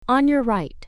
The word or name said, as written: on your right